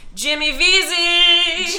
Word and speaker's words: Vesey: Jimmy Vesey. – Jimmy Vesey. – Easy Vesey, as we like to call him, so I can remember how to pronounce his name.